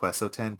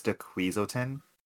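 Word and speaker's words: Weselton: It's funny, they actually did a double joke, because in Frozen, they had a character named Duke Wesselton, but everyone pronounced it as Duquesleton.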